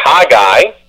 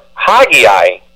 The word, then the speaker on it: Haggai: It can be said as either Haggai, with an emphasis on the H-A, or Haggai. I always used to say it as Haggai, with an emphasis on the G-G-A-I, but you can say it as you please.